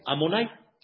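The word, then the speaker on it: Adonai: Ese nombre no se pronuncia tal cual como está. Ese nombre se pronuncia de una forma diferente. ¿Cómo se pronuncia ese nombre? ¿Cómo se pronuncia ese nombre? Amonai. – Ese nombre se pronuncia Amonai.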